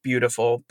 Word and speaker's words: Biutiful: I think it's pronounced Beautiful, but it's spelled B-I-U-T-I-F-U-L.